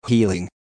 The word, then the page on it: healing: American English